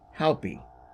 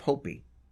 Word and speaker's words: Hopi: And the pronunciation I looked up said more like Haupi instead of Hopi.